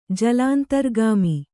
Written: ಜಲಾಂತರ್ಗಾಮಿ

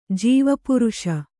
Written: ಜೀವಪುರುಷ

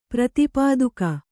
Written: ಪ್ರತಿಪಾದಕ

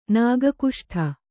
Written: ನಾಗಕುಷ್ಠ

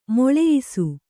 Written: ಮೇಳಯಿಸು

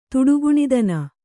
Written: ತುಡುಗುಣಿದನ

